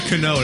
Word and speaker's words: Cano: How do you say her name? Cano? Oh, is that like... It's like Robbie Cano. Alicia Cano, that's his pick.